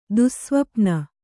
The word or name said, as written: ದುಸ್ಸ್ವಪ್ನ